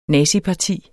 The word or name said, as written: naziparti